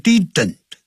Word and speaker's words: didn't: And, 这边用了并列连词，连接两个主语，接下来的主语是这么说 ,I didn't like to, 这个 didn't 也是过去时的助动词，不要念成 didn't，叫 didn't 的音用嗯的音，好，这样 didn't，像我是学生 I am a student，念快的时候变成 I am a student 嘴巴不是闭起来，是张开的念不是好 I didn't like to memorize words.